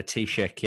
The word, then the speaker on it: Taoiseach: Is it the Taoiseach? – Yeah, the Taoiseach, yeah.